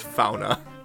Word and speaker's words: fauna: I just want fauna to rhyme with sauna, but, you know, the way they say it in Finnish? – Finnish.